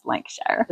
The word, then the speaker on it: Lancashire: A couple from Lancashire. – Lancashire. – I think I even said that right. You guys can correct me if I'm wrong, but I'm pretty sure it's Lancashire.